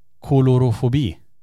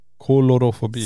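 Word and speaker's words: coulrofobi: Ska försöka uttala det här. Kolorofobi. – Kolorofobi. – C-O-U-L-R-O-fobi var den som hade skrivit.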